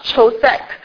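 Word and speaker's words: chosek (חֹשֶׁךְ): 这个黑暗这个希伯来文，它的读音是 chosek，它这个夜晚它的读音是 laila。就是他，你听到希伯来人读的时候，你会觉得黑暗的是那种特别短促急促的发音，但是这个夜晚是那种是长音，非常平和的。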